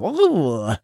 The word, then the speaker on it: oeuvre: And he looked up to me and said, oeuvre.